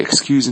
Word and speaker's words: excuse: 那么 excuse me, 这个 excuse 呢它的发音是 excuse 啊，注意最后那个 S 发的是 z 的音，浊辅音，表示原谅。这里呢明显是一个名词吧，因为它前面是个冠词 an, 对吧，所以这个发音是 excuse, 最后那个 S 是发的清辅音是 s。 OK 啊 ,excuse, 一个是 excuse, 好，再往后 ,execute, E-X-E-C-U-T,